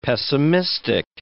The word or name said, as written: pessimistic